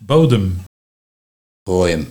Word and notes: Bòòjem: Nieuw lekkerlands

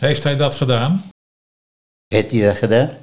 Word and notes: Hettie dat gedaan: Nieuw lekkerlands

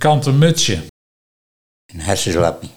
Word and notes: Harseslappie: Nieuw lekkerlands

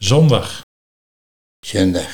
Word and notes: Zundag: Nieuw lekkerlands

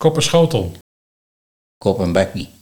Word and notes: Kop en bakkie: Nieuw lekkerlands

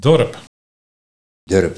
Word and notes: Durp: Nieuw lekkerlands

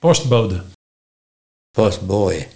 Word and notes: Postbooje: Nieuw lekkerlands

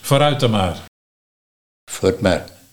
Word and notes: Vort mar: Nieuw lekkerlands

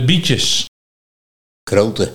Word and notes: Krote: Nieuw lekkerlands

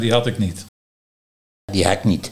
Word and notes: Die hak nie: Nieuw lekkerlands

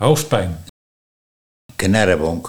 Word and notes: Knarrebonk: Nieuw lekkerlands

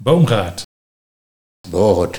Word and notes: Boogerd: Nieuw lekkerlands

